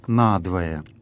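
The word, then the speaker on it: на двое: «Надвое» — вот это словосочетание в церковнославянском тексте пишется в два слова, в отличие от русского слова «надвое». Здесь в церковнославянском это два слова, и ударение такое — «надвое», то есть «на две половины».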